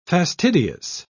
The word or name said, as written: fastidious